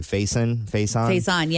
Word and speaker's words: Faison: Faison, yeah.